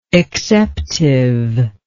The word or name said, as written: exceptively